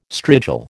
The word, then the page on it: strigil: American English